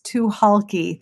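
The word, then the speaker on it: Tucholke: It is T'Chulkey. The C is silent.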